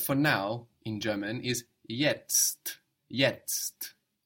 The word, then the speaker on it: jetzt: So it's like the English word yet with an ST, an extra ST. So if this is complicated to pronounce, you can think of the English word yet, and then after you think of that, add on the ST, jetzt.